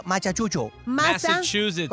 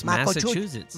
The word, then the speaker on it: Massachusetts: Esto pasó en Machachucho. – Massachusetts. – ¿Cómo se dice? Nunca, siempre he tenido problemas. Perdón, mis oyentes. Tengo hasta novios en Machuchu. – Massachusetts. – It's Massachusetts.